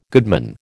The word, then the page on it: goodman: American English